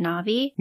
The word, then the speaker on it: Nebi: But in the actual translation during the drama, it's spelled N-E-B-I, and it's pronounced closer to Nebia as opposed to Navi.